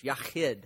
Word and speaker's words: Yahid: Yahid. If you say it right, you're going to spit on somebody. Yahid.